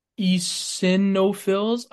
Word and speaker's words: eosinophils: Another really important factor for asthma, it's thought that eosinophils, I'm not sure how to pronounce it, but it's Eosinophils. – eosinophils. Okay. It's EOSI and then no fills.